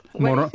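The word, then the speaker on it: Morón: The town on the Caribbean coast is M-O-R-O with an accent over N. How do you pronounce that town? – Moron.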